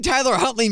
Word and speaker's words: Tyler Huntley: Because just the name Tyler Huntley makes me want to just break things at this point. I'm traumatized after last week. – Hey, you said it right. You said it right this time.